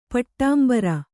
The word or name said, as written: ಪಟ್ಟಾಂಬರ